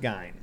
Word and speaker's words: Gein: It's in German, it's Gein.